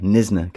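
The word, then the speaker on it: Knysna: And we're talking about Bradypodian damaranum, otherwise known as the Nisner chameleon, with a silent K, Nisner chameleon.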